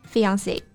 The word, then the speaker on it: fiancée: 那这个表达呢，大家肯定在电影里面经常听到，未婚夫 fiance，未婚妻 fiance。 – That's right，这两个词都是来自于法语，虽然说拼写不一样，但是读音是一样的。